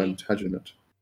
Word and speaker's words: Plantagenet: clan. – Soft G. – Plantagenet.